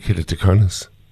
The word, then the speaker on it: keratoconus: I've not heard of the condition, and I hope I'm pronouncing it right, keratoconus. – I think you got it absolutely perfectly.